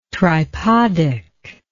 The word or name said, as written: tripodic